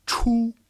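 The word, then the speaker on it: chu (出): So there is a CH sound in Chinese, which is often associated with the character for to go out, which is pronounced chu.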